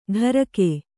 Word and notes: ಢರಕೆ: ḍharake

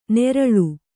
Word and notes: ನೇರಳು: nēraḷu; Kannada

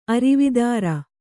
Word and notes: ಅರೆವತ್ತರು: kannada

